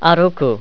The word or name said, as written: aruku